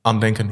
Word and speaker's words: Andenken: And Andenken, How do you pronounce it? – Andenken. Oh, yeah, the German Andenken.